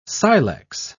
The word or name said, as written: silex